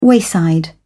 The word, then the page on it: wayside: British English